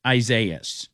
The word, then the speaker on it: Isaias: It's Isaiah. It's like Isaiah, but it's two of them. So it's Isaias. I don't care what anybody else calls it. I don't care who calls it. It's Isaias.